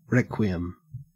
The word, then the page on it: requiem: Australian English